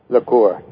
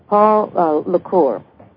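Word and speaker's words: Poul la Cour: But also, and you may correct me, please, if I do not pronounce his name correct, the Danish wind energy pioneer, Paul Alur. – Lecour. Yes. – Lecour. Paul Lecour.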